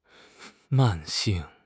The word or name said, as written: 慢性